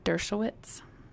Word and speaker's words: Dershowitz: So, then you get to hear from Harvard professor Alan Dershowitz.